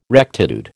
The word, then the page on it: rectitude: American English